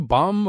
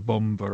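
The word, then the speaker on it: bombe: do you say bomb or bomba? How do you pronounce that last E? – Bomba is used by some people, although some other people say bomb because it means, in a sense, bomb, I think.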